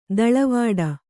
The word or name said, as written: ದೂಳುವಡೆ